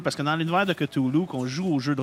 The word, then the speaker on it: Cthulhu: Non, c'est Cthulhu. C'est comme ça qu'on le prononce. – Cthulhu. – C'est que si vous avez appris quelque chose au moins ce soir, c'est qu'on dit Cthulhu.